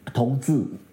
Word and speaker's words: 同志: So in the book, you compare 同志, did I say it right that time? – 同志. – 同志, you gotta get that Z down, Jonathan.